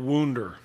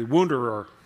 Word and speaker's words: wounder: We might call him the loyal wounder, or if you want to say it incorrectly, wounderer. Loyal wounderer.